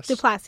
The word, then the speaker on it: Duplass: Duplass, yeah.